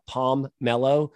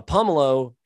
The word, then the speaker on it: pomelo: I've never heard someone say it's a palm mellow. Um, but a pomelo is like I said,